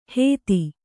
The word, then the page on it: ಹೇತಿ: Kannada